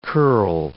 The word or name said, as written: curl